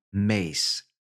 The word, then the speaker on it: Myss: Now, there's different opinions about how to pronounce that. Okay, just did a quick Google search. Caroline Mace, Myss.